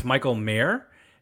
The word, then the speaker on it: Michael Mayer: It's Michael Mayer, like the guy who runs a city.